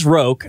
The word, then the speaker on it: Roque: R-O-K-U-E. It is Roque. I've looked this up several times, and I always want to say Roque because it's a variant of croquet, but it's called Roque.